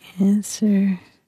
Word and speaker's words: answer: Or answer? – W. – W, yeah.